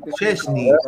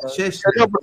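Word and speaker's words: Szczęsny: Chesney, Chesney.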